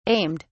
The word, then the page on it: aimed: British English